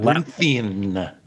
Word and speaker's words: labyrinthine: I always mispronounce this word. – Labyrinthine. – Yes, that.